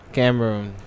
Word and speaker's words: Cameroon: Or Cameron? Cameroon? – Cameroon. – He said Cameron.